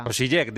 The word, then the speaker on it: Osijek: Club Osijek croata. – Osijek, digo yo, pero bueno, Osijek, sí, Osijek.